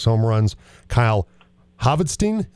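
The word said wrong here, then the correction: Hvidston: am I getting that right? – Vidston, H is silent. Vidston, thank you. – Like the Viking, yeah. – All right. Kyle Vidston, thank you.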